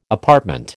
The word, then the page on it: apartment: American English